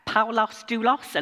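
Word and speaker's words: Paulos doulos (Παῦλος δοῦλος): In Greek, it's Paulos doulos, and if you say that right, it rhymes, apparently, but not in a Yorkshire accent, clearly.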